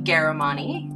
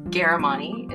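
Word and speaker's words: Ghahremani: Well, my name is Susie Garamani. The H's are just throw people off. So Garamani is the way that it's pronounced.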